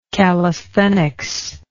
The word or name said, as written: callisthenics